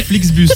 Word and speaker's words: Flixbus: Alors, est-ce qu'on est sûr de la prononciation du bus ? Comment t'appelles ça, toi ? T'appelles ça comment ? – Un Flexibis. – Un Flexibis. – Je crois que c'est un Flixbus.